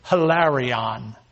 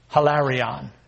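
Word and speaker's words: hilarion (ἱλαρόν): By the way, the word cheerful in the Greek is pronounced this way, hilarion. hilarion.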